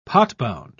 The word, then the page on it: potbound: British English